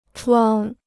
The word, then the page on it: 闯: chuǎng